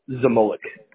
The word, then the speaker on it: Zmolek: Zmolek.